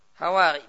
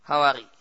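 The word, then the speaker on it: Hawari (الحواري): Hawari, bi fatil ha, wa kasira, Hawari.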